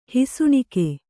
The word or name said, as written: ಹುಸಿನಗೆ